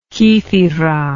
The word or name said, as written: kythera